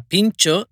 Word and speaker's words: pintxo: Se escribe P I N T X O. Pincho, que es la palabra en euskera.